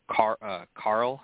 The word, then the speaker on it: Qarl: But to move on to talk about her champion, Asha's champion, Carl, I believe is how it's pronounced, Q-A-R-L.